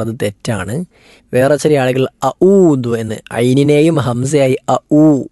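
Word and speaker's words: അ ഊദു (أعوذ): അ ഊദു എന്നാണ് ശരിയായ ഉച്ചാരണം, ബാക്കിയെല്ലാം തെറ്റാണ്.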